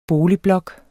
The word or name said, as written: boligblok